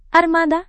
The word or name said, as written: armada